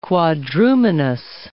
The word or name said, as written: quadrumanous